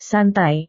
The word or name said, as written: santai